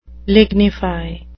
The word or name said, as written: lignify